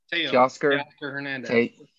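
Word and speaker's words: Teoscar Hernández: Teoscar Hernandez.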